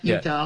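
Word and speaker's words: Eutaw: Utah. That's E-U-T-A-W, Utah. – Yeah, that's how you pronounce it, Ohio.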